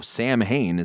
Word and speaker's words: Samhain: Well, Samhain, for our listeners, if you don't know, Samhain is actually pronounced Samhain. The M is silent.